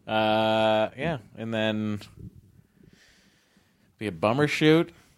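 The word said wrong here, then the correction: Bumbershoot: It sounded like you said bummer shoot. – Hey, it is. – Bumber shoot, yeah.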